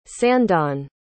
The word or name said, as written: sandon